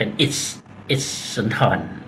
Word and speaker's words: อิศรสุนทร: หรือบางคนก็จะออกเสียงแบบไม่แบบเป็นภาษาสันสกฤตก็เป็นอิสสุนทรนะฮะแต่ถ้าออกเสียงแบบให้ครบเสียงเนี่ยก็คืออิสสอนสุนทรนะครับ